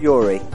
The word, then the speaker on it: Yuri: Yes, it's D-E-N-N-I-S. – And your name? – It's Yuri. – Yuri, can you spell that? – Yes, it's Y-U-R-I.